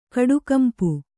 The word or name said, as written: ಕಡುಗಂಪು